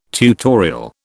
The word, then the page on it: tutorial: British English